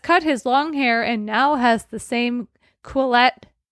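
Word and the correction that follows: Quileute: Nope, I said it wrong again. – Quillute tattoo on his upper right arm as Sam and the other tribe members.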